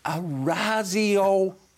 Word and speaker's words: Orazio: It's a softer Z sound. – How should it be? – Orazio, not Orazio. – I don't say Orazio. – Give it to me. Tell me. – Orazio Fantasia.